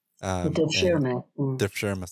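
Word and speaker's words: devşirme: Going along with that pragmatic approach, Diana, you also explain a bit about the, I'm not sure if I'm pronouncing this right, the Dervishma system. Dervishma system, yes. And you write that the type of meritocracy that was incorporated into that in that system is hard to achieve even today.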